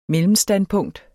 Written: mellemstandpunkt

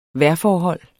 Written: vejrforhold

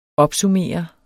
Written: opsummere

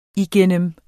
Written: igennem

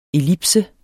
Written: ellipse

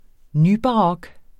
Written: nybarok

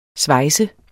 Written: SVEJSEDE

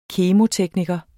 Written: KEMOTEKNIKERE

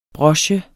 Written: broche

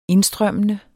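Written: indstrømmende

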